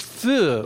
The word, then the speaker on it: pho: And it's long been settled in Vietnam that it's pronounced "fuh" or however you pronounce it.